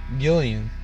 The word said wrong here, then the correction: Guillermo: Oh, Guillermo.